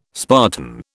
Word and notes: Spartan: British English

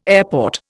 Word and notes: airport: Indian

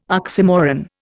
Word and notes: oxymoron: Indian

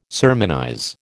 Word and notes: sermonize: American English

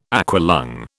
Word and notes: aqualung: British English

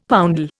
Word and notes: poundal: Indian

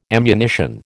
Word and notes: ammunition: American English

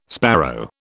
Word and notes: sparrow: British English

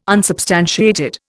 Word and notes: unsubstantiated: Indian

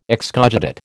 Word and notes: excogitate: American English

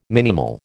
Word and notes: minimal: American English

